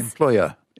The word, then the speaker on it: employer: For entrepreneur, but for the person who has employees is employer. Employer. Yeah. It's an vowel because the stress is on the oi.